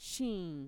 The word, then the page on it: shiin: Mixtec